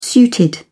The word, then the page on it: suited: British English